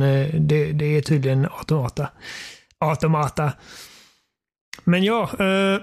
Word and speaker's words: Automata: eh, men du hade klarat ut eh, Near Automata. – Ja, eller som jag har lärt mig att man faktiskt uttalar det automata.